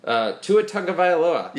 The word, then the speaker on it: Tua Tagovailoa: Tua Tagovailoa.